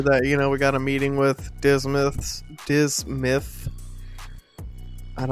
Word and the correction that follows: Dismas: You said it right the first time, Dismas.